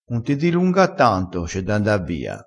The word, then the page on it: dilunga: dialettale